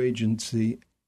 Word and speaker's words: AGENZ: So I mentioned in the intro your new agency, A-G-E-N-Z, pronounced agency, as your in-house creative agency.